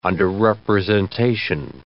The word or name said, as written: underrepresentation